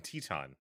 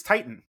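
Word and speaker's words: Titán: With Angel De Oro and Cavernario and Teton, it says from the Observer. – Yeah, title. Yeah. Which is, by the way, and apparently we've been wrong, it's Titan. It is pronounced Titan in CMLL.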